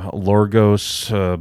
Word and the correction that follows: Yorgos Lanthimos: Yorgos Lanthimos.